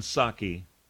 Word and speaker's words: Psaki: Something we're learning about 46, and this comes straight from his press secretary, Jen Psaki. The P is silent. Again, P-S-A-K-I.